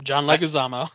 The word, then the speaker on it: John Leguizamo: John Leguizamo, right? That's how you say it, isn't it? – Yep, John Leguizamo.